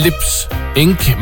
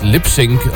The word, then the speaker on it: Lips Inc.: Og på skærmen, der står der Lips Inc., men det skal faktisk udtales Lips Inc., om vi er egentlig tilbage i 1980.